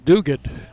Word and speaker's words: Diuguid: You might uh, be wondering what what what the young man's name is, but it is spelled D-I-U-G-U-I-D and pronounced Duguid.